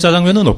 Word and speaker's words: 짜장면: 짜장 작장면을 중국 발음으로 읽으면 짜장면 이렇게 되잖아요.